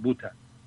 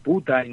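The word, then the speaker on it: Butter: Y en alemán la mantequilla se, se pronuncia para que lo entendamos, muy parecido al inglés, se escribe muy parecido al inglés, pero la pronunciación es más cerrada y entonces más o menos queda como puta Y, aquí se entendió como puta.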